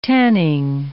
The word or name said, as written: tanning